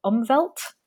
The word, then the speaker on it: Umwelt: And I'm wondering if you can kind of tell us about your conception of world making and the concept that I'm definitely going to butcher the pronunciation of Umwelt. – That's pretty good.